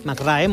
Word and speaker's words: McRae: McRae, molt bé. – No em va semblar malament. – No, així saps com s'escriu. – Clar. – O sigui, directament. – Ho va pronunciar com s'escriu. Tate McRae.